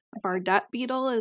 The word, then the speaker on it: Bardet-Biedl: Am I saying it correctly? – You are. I don't think I always say it properly. I think you said it better. Bardet-Biedl is how it's most commonly pronounced. Bardet-Biedl is also something that somebody, you know, some people say.